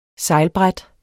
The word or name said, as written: sejlbræt